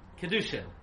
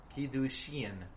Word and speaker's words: Kiddushin: If you pronounce the word Kedushin, you're pronouncing it incorrectly. The word is Kiddushin. Ian. Why? Why do I say that? Because there's a Yud. Right? Kuf, Dalid, Vav, Shin, Yud, final nun.